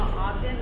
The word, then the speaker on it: Ogden: Hi, I'm Kima Ogden. Last name is spelled O-G-D-E-N. – Is my last name from Kima? – Ogden, O-G-D-E-N.